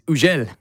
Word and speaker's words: Hugel: Also, just realized we've been mispronouncing it. It's Ugel. Ugel, Moving to the Sun.